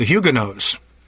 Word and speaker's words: Huguenots: The Huguenots. Huguenots, we usually call them, but the French would pronounce that Huguenot.